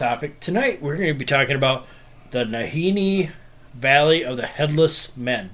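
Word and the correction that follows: Nahanni: Did I say that right? – Uh, Nahani, yeah.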